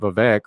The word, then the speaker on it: Vivek: She did finally have the great one line about Vivek, rhymes with fake, rhymes with snake.